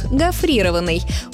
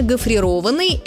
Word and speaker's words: гофрированный: При этом, скорее всего, они поставят ударение на второй слог – гофрированный. Увы, этот вариант хоть и распространенный, но неправильный. Нормативное ударение «гофрированный» – это причастие образовано от глагола «гофрированный».